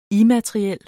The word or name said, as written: immateriel